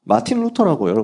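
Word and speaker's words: Martin Luther: 마틴 루터라고 여러분 많이 들으셨죠? 영어식 발음이 마틴 루터죠. 마르틴, 독일어식으로. 자기가 독일 사람이니까. 마르틴 루터입니다.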